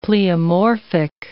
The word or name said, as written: pleomorphic